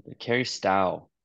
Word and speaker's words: Stough: Stough, rhymes with dairy cow. – All right. Cary Stough, who reviewed Michael Palmer's The Danish Notebook, recently re-released by Nightboat.